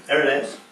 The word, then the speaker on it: Irenaeus: And this, if you again with Vince's lecture, is very much the thinking of Irenaeus, how do I pronounce that? – Irenaeus, yeah.